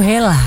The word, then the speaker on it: hela: Mungkin salah satu sahabat suara yang ngegini soal hari ini juga baru tahu bahwa cara membaca hela itu hela.